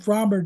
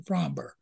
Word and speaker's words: Framber: Something that kills me, I'm so tired of people mispronouncing Framber. All the Fox guys call him Framber, and it's Framber. And Dusty calls him Framber. I want to see Dusty, he's your pitcher. You've been here 3 years. Pronounce his name right.